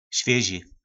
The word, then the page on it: Svjyzi: Po našimu